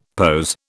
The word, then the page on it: POS: British English